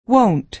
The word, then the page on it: won't: American English